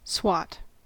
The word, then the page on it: swat: American English